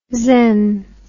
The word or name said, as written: xen